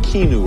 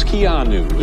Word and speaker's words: Keanu: Thanks for mispronouncing your name. I was saying Keanu or something. It's Keanu. Is that correct? – I love movies.